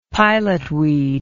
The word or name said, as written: pilotweed